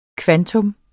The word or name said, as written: kvantum